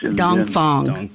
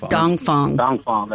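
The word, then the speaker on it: Dong Phuong: Between, I may pronounce this wrong, but Dong Quam, it's a Vietnamese bakery widely known for king cakes. – Dong Phong. – Dong Phong, that's it. There you go.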